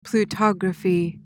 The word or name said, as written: plutography